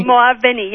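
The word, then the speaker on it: Moaveni: She wrote I want to make sure I pronounce her name right, Azadeh Moavani? – Moavani, yes.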